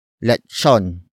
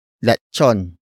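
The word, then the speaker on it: letson: Lechon. Lechon.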